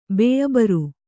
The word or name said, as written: ಬೇಅಬರು